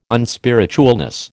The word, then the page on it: unspiritualness: American English